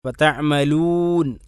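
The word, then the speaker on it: തഅമലൂൻ (تعملون): തഅമലൂൻ തഅമലൂൻ തഅമലൂൻ എന്ന് തന്നെ പറയണം. അതിന് ശരിയായ ഉച്ചാരണം തഅമലൂൻ, വാവിനെ ശരിക്കും ചേർത്തിക്കൊണ്ട് ചുണ്ട് ചേർത്തിക്കൊണ്ട് തന്നെ പറയണം.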